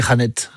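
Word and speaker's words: Jeanette: Moi je dis Jeannette. – Ouais, non mais on aime bien, jolie prononciation. – À l'espagnol, ouais.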